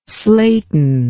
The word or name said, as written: Slayton